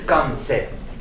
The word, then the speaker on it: Kwansei: Ils écrivent, quand ils écrivent alphabétiquement, leur nom avec un W, bien qu'on prononce Kansei.